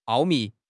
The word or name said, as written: 奥秘